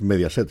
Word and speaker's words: Mediaset: No, no, no, es Mediaset.